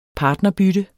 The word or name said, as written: partnerbytte